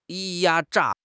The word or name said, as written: 压榨